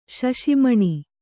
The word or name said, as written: ಶಶಿಮಣಿ